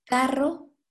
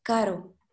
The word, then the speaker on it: carro: Or the pronunciation of the R, double R. For example, carro versus caro. Carro, caro. Carro means car, caro is expensive.